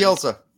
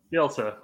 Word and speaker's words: Bielsa: Bielsa. – Bielsa.